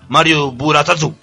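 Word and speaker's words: Mario Buratatsu (マリオブラザーズ): Y en Japón se pronuncia Mario Buratatsu.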